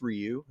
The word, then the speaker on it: Ryu: they said, okay, it's pronounced Ryu.